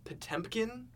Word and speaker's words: Potemkin: This was recently we all got to have a screening of the classic 1925 Soviet film Battleship Potemkin. – Correct. – I'm saying that right? – Yes, you are.